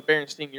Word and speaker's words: Berenstain: Well, when you think of a Berenstain, you're thinking, like, the last name, and it's like Stein, more or less. So it's spelled Stain, not S-T.